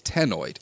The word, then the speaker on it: ctenoid: Their scales, if you want to go by shape of scale, is ctenoid. C-T-E-N-O-I-D. The C is silent, like in cnidaria. Ctenoid scales.